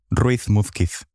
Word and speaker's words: Ruiz-Múzquiz: Ruiz-Múzquiz.